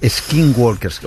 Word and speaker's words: Skinwalkers: Tenía algo que ver con Star Wars, pero Skinwalkers, o Skinwalkers, que además sería más la pronunciación.